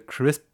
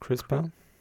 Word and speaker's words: CRISPR: Aber ähm, ich glaube, wenn ich das richtig verstanden habe, diese CRISP, CRISP oder wie, wie spricht man es aus? CRISPR.